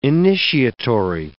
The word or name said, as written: initiatory